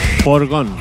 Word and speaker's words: Foregone: Foregone.